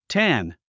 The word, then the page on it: tan: tǽn; タン